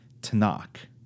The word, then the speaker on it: Tanakh: And it goes by this word that's spelled T-N-K, Tanakh. Because in Hebrew, they don't have original Hebrew, proto-Hebrew, doesn't have vowels. It just has consonants. And so the Hebrew Scriptures are ordered by that word, Tanakh.